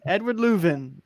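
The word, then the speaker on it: Löwen: At the beginning of the year, I couldn't even say his last name. I was like, Edward Lowen? Like, what is that? Edward Leuven. He's in my lineup. I got to say his name right.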